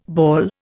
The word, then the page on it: bawl: Indian